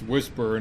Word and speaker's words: WSPR: WSPR, pronounced Whisper, and which stands for Weak Signal Propagation Reporter.